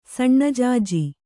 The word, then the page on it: ಸಣ್ಣಜಾಜಿ: kannada